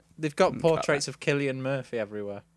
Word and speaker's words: Cillian Murphy: It's Cillian Murphy.